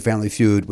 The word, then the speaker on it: Family Feud: Can I tell you how grateful I am that you said Family Feud with the accent on the right word that second time? The first time you said it, Family Feud. And it was like, oh, that's, nobody says it that way, and I'm not going to allow it.